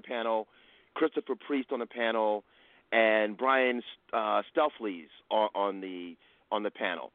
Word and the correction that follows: Stelfreeze: Am I pronouncing his name correctly, Daryl? – It's close enough. I always use Stelfreeze, so.